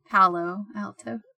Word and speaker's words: Palo Alto: Pablo Alto University in the late 1980s. – Pablo Alto? – Palo Alto? I can't pronounce shit. – Wait, P-A-L-O? – Yeah.